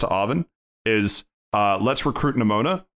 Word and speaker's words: Nemona: Nemana. – Nemana and... – Nemona. Oh yeah, it's Nemona, not Nemana.